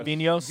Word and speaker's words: Vinhos: Vinhos. V-I-N-H-O-S.